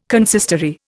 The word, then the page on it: consistory: Indian